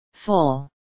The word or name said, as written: full